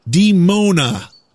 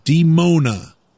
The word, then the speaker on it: Dimona: Dimona. Female demon. Dimona. Even though it's spelled D-I-M-O-N-A.